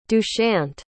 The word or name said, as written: dushyant